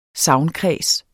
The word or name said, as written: sagnkreds